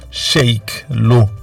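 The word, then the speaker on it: Cheikh Lô: Il y a plusieurs années, on a beaucoup joué des chansons de Cherilo. Sheikh Lo, pour le prononcer un peu plus lentement, elle-même Adjouza.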